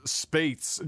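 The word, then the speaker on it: Spaihts: John, I think this is pronounced Spates.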